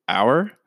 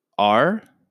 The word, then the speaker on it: our: So the word is our, O-U-R. So it can be pronounced our or R.